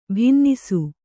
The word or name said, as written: ಭಿನ್ನಿಸು